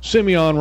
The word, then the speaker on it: Simeon: I showed you that the week before when I said this is what they need to do to Simeon, because that's how they pronounce it, Simeon Rice.